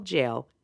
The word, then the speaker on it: Gaol: So they spell jail, Gaol. So it's Parramatta Jail Ghost Tours .com.au.